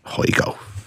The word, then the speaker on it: Højgaard: If that's Rasmus Højgaard? – Yes, Højgaard.